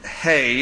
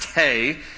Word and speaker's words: He: Now, it's the Hebrew letter is just spelled H-E. So in English, we would look at that and say it's He, but it's pronounced Hey in Hebrew.